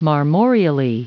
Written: marmoreally

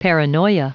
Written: paranoia

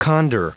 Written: condor

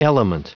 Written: element

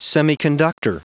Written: semiconductor

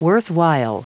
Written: worthwhile

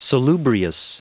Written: salubrious

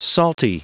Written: salty

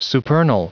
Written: supernal

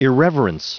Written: irreverence

